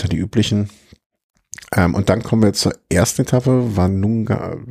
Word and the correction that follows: Tanunda: wie habe ich es jetzt eben ausgesprochen? Tanunda nach Tanunda.